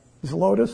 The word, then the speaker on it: Zelotes: And that's why in Luke he's called Ziotis? I can't pronounce that word. Someone's got a better way? – Zelotes. – Zelotes, that is how it is.